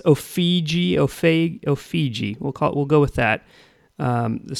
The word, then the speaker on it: Ofege: The band is called, I think they're pronounced Ofege, O-F-E-G-E is the way you spell it.